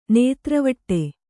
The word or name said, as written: ನೇತ್ರವಟ್ಟೆ